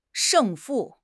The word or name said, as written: 胜负